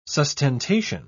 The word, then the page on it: sustentation: British English